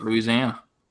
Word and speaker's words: Louisiana: That's how you say it, Louisiana.